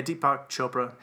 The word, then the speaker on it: Deepak Chopra: And I was going to mention that Deepak Chopra, how do you say that? – Yeah, Deepak Chopra, that's right.